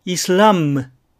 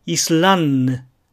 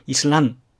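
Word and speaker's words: islam: 最初の単語は、アルブムではなく、アルブンヌ。次の単語は、イスラムではなく、イスランヌ。で、実際には、ヌなんて言わないで、イスランというだけなんですが、このように発音します。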